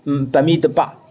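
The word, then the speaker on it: Bemidbar: 希伯来语发音是 Bamidha 啊，就是这个 Bamidba，在旷野，它原来的名字。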